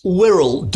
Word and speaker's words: Wirral: It's Wirral, W-I-R-R-A-L. It's the Wirral.